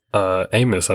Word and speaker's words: Abmas: Yeah, that Max Amos, I believe his name is spelled A-B-M-A-S, but I think the B is silent.